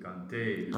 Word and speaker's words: Kanté: Kanté.